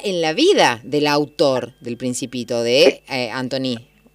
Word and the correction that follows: Antoine: No sé si estoy pronunciando bien el francés. Perdón, Facundo Arzamendi. – Antoine. Bueno, yo tampoco tengo un francés, así que no puedo calificar como buen. Pero bueno, sería, nosotros le decimos Antoine.